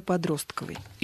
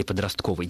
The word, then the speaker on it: подростковый: Так, и вот интересуется, где надо поставить ударение в слове «подростковый». – Или «подростковый». Дело в том, что современные орфоэпические словари оба слова дают, как, оба варианта дают как равноправные, и никакого различия между ними нет.